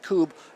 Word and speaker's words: Kubb: It looks like Cub, K-U-B-B. Is pronounced Coob.